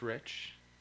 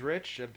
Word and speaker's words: Rich: My name is Rich. Oh wow, you ready? I didn't forget my name. My name's Rich. – Can you spell that for us? – It's R-I-C-H.